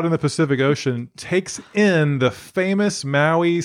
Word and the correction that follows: Moai: statues of the island. – No. – I don't think I said that right still. – No, that's okay. – Moai.